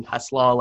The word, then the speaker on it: Hesslau: So if you want to find me on Facebook, just search Ryan Hesslau.